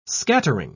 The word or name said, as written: scattering